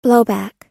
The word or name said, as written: blowback